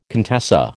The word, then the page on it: contessa: American English